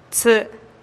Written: tsï'

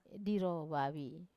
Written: diro vavi

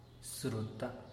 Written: sruta

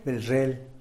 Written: belzhel

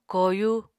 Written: koyu

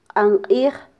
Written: qanqij

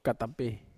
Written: katape

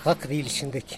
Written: gakdilyxindeky

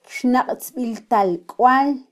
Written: kyxnaqtzbiltalkwal

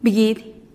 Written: bigidih

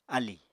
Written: alì

